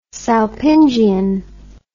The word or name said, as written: salpingian